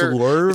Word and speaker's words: Louvre: Right, it's pronounced Louvre.